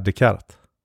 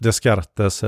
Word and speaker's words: Descartes: För om du inte kan franska så, och bara läser en bok, så gissar du ju inte att man ska säga Descartes. – Nej. – Du gissar ju på typ Descartes eller Descartes eller någonting.